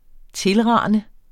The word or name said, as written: TILRANEDE